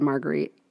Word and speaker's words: Marguerite: And it is about a girl named Magritte, I think. Magurite. – I think it's spelled, said Marguerite. – Marguerite? – Like Aunt Maggie. Her name was Marguerite. – Well, I'm saying her name now, Margrit Utley.